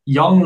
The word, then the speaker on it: Young: Young like young age.